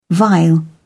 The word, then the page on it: vile: British English